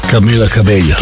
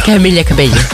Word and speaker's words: Camila Cabello: Camila Cabello. – Camila Cabello.